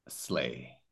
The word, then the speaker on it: sleigh: So this one, the pronunciation is sleigh. Do you see it? Yeah, sleigh. And that's the pronunciation.